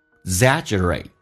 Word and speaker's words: exaggerate: exaggerate，那个 g 这个发音可以轻一点点，似听到没有听到，到两个辅音在一起的时候 exaggerate exaggerate。 OK，我们整一段慢速版本来练习一下。